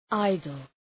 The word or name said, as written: idle